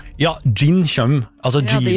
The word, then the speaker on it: jinn: Gin tonic, ja. Nei, ja, det skrives med jod. J-I-N-N. Gin. Men også det uttales gin. For på engelsk skrives det med D først. Gin.